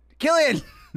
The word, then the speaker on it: Cillian: We thought his name was Cillian. You come on my FYP, you pronounce my boy's name right, Killian!